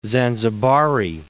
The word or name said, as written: Zanzibari